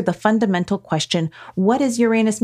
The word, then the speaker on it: Uranus: I do remember Bill Nye, the science guy, deliberately making sure to pronounce it Uranus.